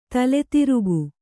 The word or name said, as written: ತಲೆತಿರುಕ